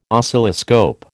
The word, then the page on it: Oscilloscope: American English